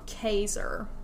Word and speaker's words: Casar: There's this place called Caesar. Now, it's spelled C-A-S-A-R. Apparently, it's supposed to be called Caesar. They spelled it wrong, so it's Caesar.